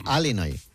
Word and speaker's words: Illinois: Se pronuncia Illinois. – Sí, perdón. ¿Perdón? Sí. – Illinois. – Pues yo digo Illinois. Y no entendemos todo. Porque como diga Illinois, nadie lo entiende.